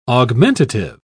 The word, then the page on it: augmentative: British English